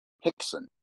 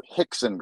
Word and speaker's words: Rickson: But I also really pay attention to Hickson Gracie. It's spelled Rickson, but it's the R's are pronounced with an H. So Hickson Gracie is also the son of Elio Gracie, but he's a champion.